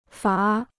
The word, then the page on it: 罚: fá